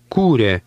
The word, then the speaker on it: Kūrė: Kūrė. – Did you hear the stress at the beginning of that word? Remember, the acute mark over the long U is a stress mark.